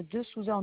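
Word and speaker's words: De Sousa: Mon nom de famille, c'est madame De Souza en deux mots. Ça s'écrit D-E, plus loin, S-O-U-S-A.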